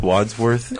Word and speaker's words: Wadsworth: Wadsworth? – Wadsworth.